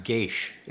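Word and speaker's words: geas: I'm sorry, it's pronounced Gaish. I know. – Oh, okay. – I had to look up. It's Gaelic in derivation.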